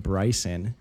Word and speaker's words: Brycen: And I've always noticed that, like, in situations like this, the name Brycen is difficult for them to understand.